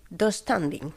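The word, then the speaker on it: döstädning: Yo lo pronuncio... Dos standing. – Sí, así. Tengo esa silla del IKEA. Sí, pronuncia así.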